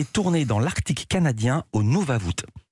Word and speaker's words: Nunavut: Au Nunavut, je le prononce bien cette fois-ci, les images sont sublimes, des images aériennes pour ce reportage.